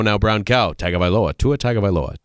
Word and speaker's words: Tagovailoa: Tagovailoa. – Tagovailoa, very good.